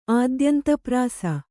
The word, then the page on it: ಆದ್ಯಂತಪ್ರಾಸ: ādyantaprāsa